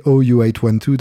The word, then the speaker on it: OU812: Mais là, en l'occurrence, c'est OU812, donc O, tu en as mangé une aussi, en réponse, bien sûr, à It and Smile de David Lee Roth.